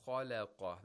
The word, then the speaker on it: خَلَقَ: Okay, وَأَنَّهُ خَلَقَ. This is one example of تَفْخِيم and تَرْقِيق in one word. خَلَقَ. – خَلَقَ. – Exactly. Only the lam has تَرْقِيق. خَا and قَا, they have تَفْخِيم.